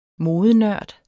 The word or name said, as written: modenørd